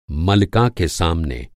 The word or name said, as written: मलका